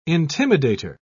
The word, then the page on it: intimidator: British English